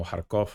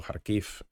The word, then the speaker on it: Kharkiv: Entonces, lugares como Kharkov o Kharkiv, eh, dependiendo de la pronunciación rusa ucraniana,